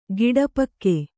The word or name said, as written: ಗುಡಪಾಕ